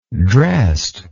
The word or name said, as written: drest